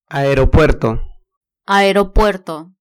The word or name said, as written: aeropuerto